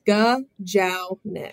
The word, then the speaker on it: Gajownik: So how do you say Gajownik? Gajownik? – It's Gajownik.